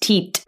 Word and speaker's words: teat: Tea leaves of Earth, along with the tit milk from cows and all of tit milk. Mm-hmm. – Yep, tit milk. Yeah. So sorry. I come from a house. – I believe it's pronounced teat.